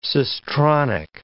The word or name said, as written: cistronic